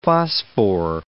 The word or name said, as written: phosphore